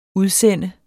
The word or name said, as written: udsende